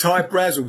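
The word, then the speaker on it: Hy-Brasil: Yeah, yeah, but it's Hi Brazil because that's how the Irish would pronounce it, because it's an okay.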